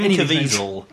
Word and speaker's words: Caviezel: No, that's what Jim Caviezel.